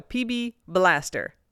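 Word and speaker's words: PB B'laster: Per their ads, the apostrophe does not get pronounced. So PB Blaster.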